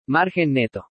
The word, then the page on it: MARGEN NETO: spanish